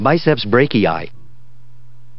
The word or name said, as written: BICEPS BRACHII